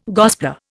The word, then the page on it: gospeller: Indian